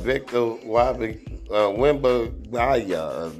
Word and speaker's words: Wembanyama: Yeah, so it's Victor Wembanyama.